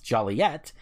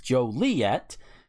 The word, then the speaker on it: Joliet: Town fathers, reflecting the pet peeve of hearing their town's name mispronounced Joliet, when all local folk know it's pronounced Joliet, made pronouncing the name of the city Joliet a misdemeanor punishable by a $5 fine.